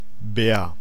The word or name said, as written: vittu